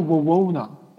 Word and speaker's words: Wawona: 这一片红杉里啊，就是也需要给大家提一提，就是说，呃，这个地方叫 Wawona，哎，Wawona，那么要不 Wawa 叫做啊，No，哎，Wawona，对，叫 Wawona 这个地方啊，比较比较奇怪，这个地方好多发音都是来自这个印第安的这些古语啊，因为它这个地方就有。